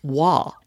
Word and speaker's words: Roy: His last name is spelled R-O-Y. So you would say Patrick Roy, and you would be wrong. His last name is pronounced Wah.